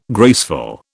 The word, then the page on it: graceful: British English